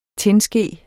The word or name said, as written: tinske